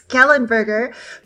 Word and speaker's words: Kellenberger: It it's Kellenberger, but actually lots of people know it as the K-Bergs here.